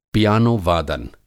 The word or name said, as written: पियानोवादन